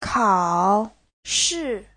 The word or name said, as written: kao3shi4